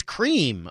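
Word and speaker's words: crème: But no, it's not creme, it's cream.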